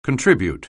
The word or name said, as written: contribute